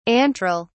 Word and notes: antral: British English

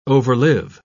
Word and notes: overlive: British English

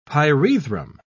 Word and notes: pyrethrum: British English

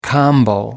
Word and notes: combo: American English

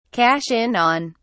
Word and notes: cash in on: British English

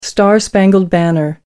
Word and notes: the star-spangled banner: American English

